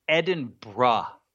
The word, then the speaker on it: Edinburgh: Well, and I'm going to save you the embarrassment of saying it wrong like an American. It's Edinburgh. – It's not Edinburgh? – It's not Edinburgh, and hate that. – It's spelled Edinburgh. – It is spelled Edinburgh, and they hate that too. It's Edinburgh, and that's the way they want you to say it.